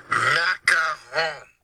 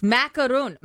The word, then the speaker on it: macaron: Macaron. Wait a minute. Is that him saying it? – Yes. Macaron. Macaron. The French pastry. The French pastry. – Why is it all of a sudden three syllables?